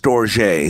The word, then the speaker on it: storge: And then there's a compound word called, I think it's called, pronounced storge. Storge, it's storge with an E on the end of it.